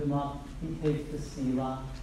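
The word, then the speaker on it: Sela (צלע): The rib here in the Hebrew is spelled T-S-E-L-A. Sela. Sela. T-S-E-L-A. Not to be confused with like salsa or Tesla. Some people would like to be a Tesla. It's Sela. And this means side.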